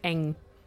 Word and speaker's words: Ng: I used to work with someone called Diane Ng, and she used to pronounce it Eng.